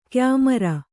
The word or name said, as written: ಕಯ್ಮರೆ